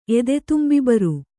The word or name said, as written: ಎದೆತುಂಬಿಬರು